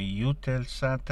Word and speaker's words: Eutelsat: Nel processo di implementazione o di preparazione all'implementazione delle suddette misure, Eutelsat, si pronuncia anche come Eutelsat, ha anche assicurato di non essere coinvolta nella trasmissione di RT News in Russia.